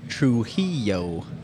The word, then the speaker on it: Trujillo: Keanu Trujillo. – Yeah, that's why I was. – Jack, I would teach Jack how to say it properly, but it would. Do you know how to roll your R's? – I can.